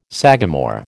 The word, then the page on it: sagamore: American English